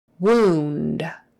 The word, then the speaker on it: wound: E-E-L, C-H, and A-I-R always is pronounced air, air, wheelchair, wheelchair, accent on the first one, wheelchair. Wound, W-O-U-N-D, and this particular word, O-U, is pronounced oo, not ow, oo. So, wound, wound, oo, oo, oo, as if it was, as if it were two O's, wound.